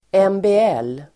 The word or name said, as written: MBL